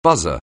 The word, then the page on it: buzzer: anglais